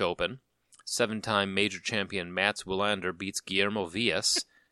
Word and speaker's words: Wilander: It's spelled M-A-T-S. – Yeah, Mats Wilander. – All right. – Yeah, the W that's pronounced V is a trick.